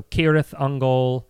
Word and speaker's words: Cirith Ungol: Into the Tolkien languages, a C is a hard C. It's a K. It's always a K sound. So Cirith Ungol.